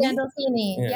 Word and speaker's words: Gandolfini: I think about Emilio Estevez on the Mighty Ducks now, and of course, you working with James Gandolfino and have I said it right? Gandolfini? – Gandolfini. Yeah, you almost Gandolfini. I knew it was wrong as soon as I said it.